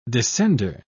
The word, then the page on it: descender: British English